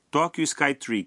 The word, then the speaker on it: Tokyo Skytree: Suponhamos que você esteja num táxi e queira ir para a torre Tokyo Skytree, Tree, que é pronunciada Tokyo Skytree. Tokyo Skytree.